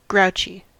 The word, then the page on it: grouchy: American English